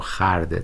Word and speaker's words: خورده: حالا شمع سر گرفته دگر چهره برفروخ، وین پیر سال خرده، که خوب خورده رو خرده تلفظ میکردن، جوانی ز سر گرفت.